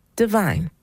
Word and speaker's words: DVYN: Well, those last four letters, DVYN, is the name of this next artist. It's pronounced Divine.